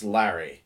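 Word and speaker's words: Larey: It's L-A-R-E-Y. – Mm-hmm. – But it's pronounced Larry.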